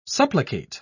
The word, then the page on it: supplicate: British English